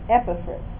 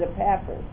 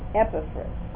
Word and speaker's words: Epaphras: See, I know because I looked up the pronunciation on Google. And it's Epaphras. So I put it on my notes here. Because for years I said Epaphras. And that was wrong. It's Epaphras.